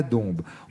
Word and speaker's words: Dombes: Alors Dombes, ça s'écrit avec un S à la fin, mais on dit bien la Dombes.